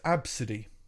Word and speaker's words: ABCDE: It's pronounced absidy. It's A, B, C, D, E. So what happened, and I understand this, Tracy Redford and her daughter, ABCDE, pronounced Absidy, were en route home to El Paso, Texas, from California.